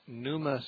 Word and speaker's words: Pneuma: The name on the sign, I think, is Numa Springs. Numa is spelled P-N-E-U-M-A. It's the Greek word for spirit.